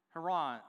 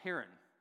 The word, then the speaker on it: Haran: So they go up and they make this trek and they go all the way up to Haran or Haran. Two ways to pronounce it.